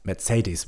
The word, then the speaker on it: Mercedes: This is Mercedes-Benz, which is not pronounced Mercedes-Benz. – It's Mercedes. – Benz. – Mercedes. – Mercedes Benz. – I'm not even saying it right.